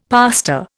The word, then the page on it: pastor: Indian